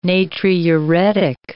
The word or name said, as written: natriuretic